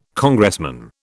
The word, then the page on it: Congressman: British English